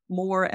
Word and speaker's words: Moore: Again, my name is spelled Meredith Moore, Moore.